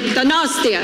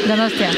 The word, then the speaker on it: Donostia: How do I say it? Donostia. – Donostia. – Donostia. Okay, yeah.